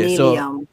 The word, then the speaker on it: Neilium: you said his name is Neelium. How does it, how you know how to spell that? How you spell that? – N-E-I-L-I-U-M. – Okay, Neelium.